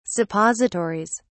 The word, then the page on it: suppositories: British English